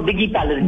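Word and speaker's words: Diggi Palace: Just lastly, the beautiful, is it pronounced Digi Palace? – It's pronounced Digi Palace, D-I-G-G-I, yeah.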